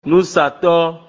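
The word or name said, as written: Núsàtɔ́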